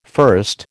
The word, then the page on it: first: American English